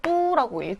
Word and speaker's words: 都: 예, 도시라고 말할 때 都라고 읽지 않고 뚜라고 읽거든요.